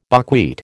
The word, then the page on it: buckwheat: American English